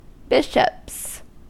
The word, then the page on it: bishops: American English